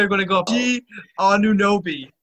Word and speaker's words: Anunoby: Anunoby. OG Anunoby. – OG Anunoby. – Yeah, it's not Anna. You got it. It's close. It's A-N-U-O-N-U-O-B. Like, it's a quick U.